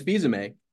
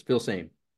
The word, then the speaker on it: Filsaime: His name is Filsaime, but when he got in the car business, people couldn't pronounce it. So years ago, decades ago, people were like, you're Filsaime. So he just went with the flow and so it's Filsaime.